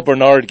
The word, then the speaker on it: Brainard: When Lael Brainard gets put into place, Jack and I came up with this piece because you say Brainard, that's kind of how you pronounce it, but you look at all you see is brain.